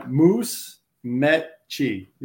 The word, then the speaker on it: Musmeci: Uh, Moose Metchi. You'd say it like that.